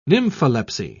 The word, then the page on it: nympholepsy: British English